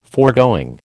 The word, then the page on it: foregoing: American English